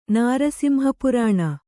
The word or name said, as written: ನಾರಸಿಂಹಪುರಾಣ